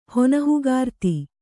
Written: ಹೊನಹುಗಾರ್ತಿ

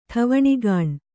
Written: ಠವಣಿಗಾಣ್

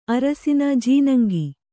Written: ಅರಸಿನ ಜೀನಂಗಿ

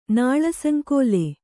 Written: ನಾಳಸಂಕೋಲೆ

